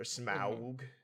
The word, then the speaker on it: Smaug: The Desolation of Smaug. Or Smaug. – That's right. Thank you for pronouncing it correctly.